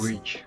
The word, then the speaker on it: grudge: Grudge.